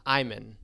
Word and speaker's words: Iman: So for the Iman Iman, it's Iman. – Okay, you were curveball. Iman. – Iman, not Iman.